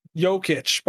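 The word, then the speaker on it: Jokić: Jokic. – Over to Jokic. Jokic, by the way.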